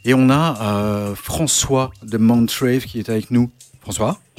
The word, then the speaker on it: Mt.Rave: Ah, déjà, avant qu'on se fasse flageller, est-ce qu'on prononce bien Montrave ou, ou autre chose Non, c'est exactement ça. C'est juste que l'écriture, c'est euh, écrit MT pour un rave, mais c'est bien Rave.